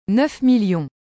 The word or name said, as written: 9000000